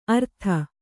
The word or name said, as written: ಅರ್ಥ